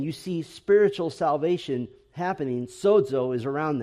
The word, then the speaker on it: sozo: And you have in both Luke and Acts the important Greek word sozo. You might spell it S-O-Z-O if you want to.